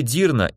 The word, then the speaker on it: Дирна: Диковинное для меня имя Харид произносилось с ударением на второй слог, а фамилия Дирна имела ударение на первый и явно о чем-то напоминала.